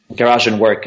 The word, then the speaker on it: Garagenberg: Garagenberg, o ¿cómo lo pronuncias tú? – Garagenberg, que es como un...